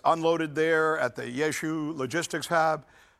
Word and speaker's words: Rzeszów: I know it's spelled weird. R-Z-E-S-Z-O-W, but it's pronounced Rzeszów, or at least that's my pronunciation, right?